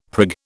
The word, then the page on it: prig: British English